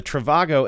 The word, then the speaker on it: Trivago: But you know, the Trivago, am I saying that right? – Yeah, Trivago.